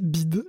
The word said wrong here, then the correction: BYD: le constructeur chinois de voitures. En fait, ça se prononce BYD pour Build Your Dream.